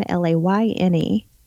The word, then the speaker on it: Layne: And you can also find Fright Cap on YouTube, and that's at Dead End Layne, spelled like my last name, Layne.